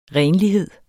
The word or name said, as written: RENLIGHED